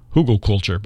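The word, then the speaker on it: Hügelkultur: It's spelled German Hugelkultur, Hügel culture, but that's how it's pronounced.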